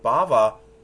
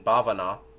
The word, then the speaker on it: bhava: Somebody asked, as in bhavana is another word, and he'll explain. There's bhava long A and bhava short A, bhava and bhava. bhavana or bhavana is connected with bhava with a long A, but their ultimate root's the same.